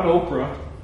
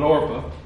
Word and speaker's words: Orpah: Orpah, not Oprah, but Orpah.